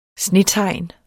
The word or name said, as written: snetegn